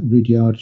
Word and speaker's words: Rudyard: I think that other names that begin with R, U and D, such as Rudy and Rudolph, they're names that have that R, U, Ru pronunciation rather than the hard U, because nobody would call somebody who's called Rudolph Rudolph, and nobody would call somebody called Rudy Ruddy. And so it does make sense that Rudyard should be pronounced that way.